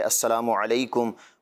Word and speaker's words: السلام علیکم: تو جو درست تلفظ ہے السلام علیکم ورحمۃ اللہ وبرکاتہ, یہی الفاظ جو ہے وہ دہرانے بھی چاہیے.